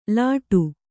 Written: ಲಾಠಿ

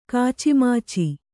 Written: ಕಾಚಿಮಾಚಿ

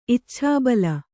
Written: ಇಚ್ಛಾಬಲ

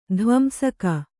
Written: ಧ್ವಂಸಕ

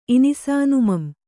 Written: ಇನಿಸಾನುಮಂ

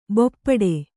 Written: ಭೂಪುಟ

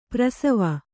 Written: ಪಾರ್ಶ್ವ

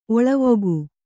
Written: ಒಳವುಗೈ

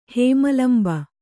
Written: ಹೇಮಲಂಬ

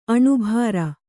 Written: ಅನಿಬರ್

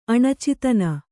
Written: ಅಣಚಿತನ

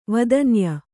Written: ವೇದನೀಯ

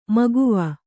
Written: ಮಗವು

